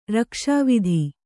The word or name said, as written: ರಕ್ಷಾವಿಧಿ